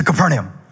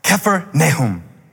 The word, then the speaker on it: Capernaum: He said, not Capernaum, Kephernaum. Took me seven times to say it until he was satisfied. He said, Kephar, Nahum. Kephar, village. Nahum, comforter. Like the minor prophet, Nahum. He said, say it again. Kephar, Nahum. Stress on the K, Kephar, doesn't matter. What it means village of the Comforter.